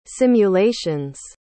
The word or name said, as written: simulations